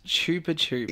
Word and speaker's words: Chupa Chups: It's pronounced Chupa Chups. – Yeah! – In the US. – Yeah, well, and Chupa Chups in the UK.